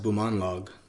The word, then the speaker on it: Bumanglag: It's actually pronounced Bumanglag.